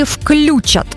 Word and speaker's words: включат: А теперь запоминаем правильный вариант. Включат. И раз и навсегда зафиксируем, глагол «включить» ни в одной форме не переносит ударение на «ю».